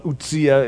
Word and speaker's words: Uzziah: Well, Uzziah is the more correct pronunciation of it, but he's called by these two different names.